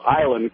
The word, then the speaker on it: Islay: island called Isla. It's pronounced, or it's spelled Islay.